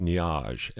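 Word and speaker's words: Nuages: did a version of Django Reinhardt's Nuage. – I believe it's pronounced Niage, and it's French for clouds.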